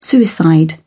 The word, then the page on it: suicide: British English